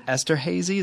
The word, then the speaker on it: Esterhazy: And you grew up in Esterhazy, is that how you pronounce that? – Yeah, potash capital of the world.